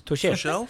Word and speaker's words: Tuchel: Tuchel.